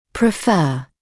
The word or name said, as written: prefer